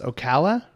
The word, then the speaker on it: Ocala: Anyway, Florida man from, I don't know how to say this, Ocala? – Yeah. – Ocala, Florida.